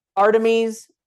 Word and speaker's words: Artemis: And that just means that I never can pronounce this right. Our timies. Armides. – Artemis.